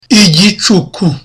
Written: igicuku

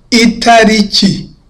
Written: itariki

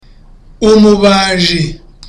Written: umubaji

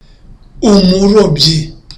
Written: umurobyi

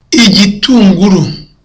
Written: igitunguru

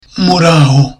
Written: muraho

